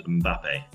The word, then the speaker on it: Mbappe: Um, well, France have France have probably got the best player in the world right now, Mbappe. Who? Um, Mbappe. His, his name's Mbappe. Okay. There's an there's an M at the front, which normally in English would be silent, but in French not, yeah.